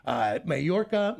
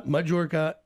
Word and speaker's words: Mallorca: Spelled two different ways, accepted and pronounced three different ways, Catalan, Spanish, and English. Mallorca, Majorca, and Majorca. Either way, it's Majorca, English, Mallorca, Spanish, and Catalan.